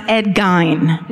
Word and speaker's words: Gein: You know, like the real true crime people would just be like, and this is true, it's actually pronounced Ed Gein. – No. – That's the correct pronunciation.